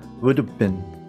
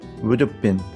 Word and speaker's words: would have been: なので、まあ、適当にこう弱く発音されます。で、結果的にどういうふうな音になるかっていうと、 would have been, would have been。はい、こんな感じで発音されます。